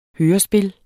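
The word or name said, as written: hørespil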